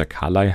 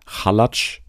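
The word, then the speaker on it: Khalaj: Dann das eine Format haben wir schon mal gehabt, hier Buff Clever Kontern mit Melissa Carley, die, wie ich gelernt habe, nicht Melissa Carley heißt, sondern Melissa Halatsch. So spricht man sie anscheinend aus.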